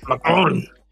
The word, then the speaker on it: Macron: So the French president, Macron, or whatever, however the fuck you say his name, Macron.